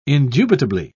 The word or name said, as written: indubitably